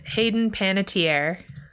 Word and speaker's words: Hayden Panettiere: So it's Hayden Panettiere.